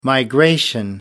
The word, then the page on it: migration: British English